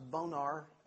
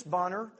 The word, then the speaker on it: Bonar: a name that we pronounce sometimes Bonar, and in my reading I found that Charles Whitfield spelled his name often in correspondence, it says phonetically, B-O-N-N-E-R.